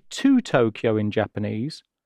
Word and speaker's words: Tokyo: The capital of Japan, Tokyo, in Japanese is pronounced as Tokyo. Tokyo. – So it's similar to English, except the O sounds in it are a bit longer.